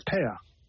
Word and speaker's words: pear: So when she says pear, like the fruit or the two of something, it's pear. There's no R at the end.